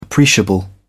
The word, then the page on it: appreciable: British English